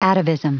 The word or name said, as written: atavism